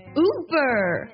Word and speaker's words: Uber: 但是其实它就是原本的这个英文的发音读作 Uber Uber。最后我们来整体回顾一下这些品牌的英文名，我先说中文再说英文。华为 Huawei，耐克 Nike，阿迪达斯 Adidas，现代 Hyundai，雷诺 Renault，保时捷 Porsche，圣罗兰 Yves Saint Laurent，优步 Uber。